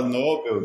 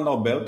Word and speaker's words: Nobel: Os dois estão certos. Certo? Quando você fala Nobel, você está provavelmente remetendo a uma pronúncia mais americana, né, de inglês. Aqui você pode falar Nobel tran tranquilo. Inclusive tem uma, uma livraria, uma rede de livraria chamada Livraria Nobel e todo mundo chama assim, Livraria Nobel.